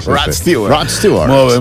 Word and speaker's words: Rod Stewart: Rod Stewart.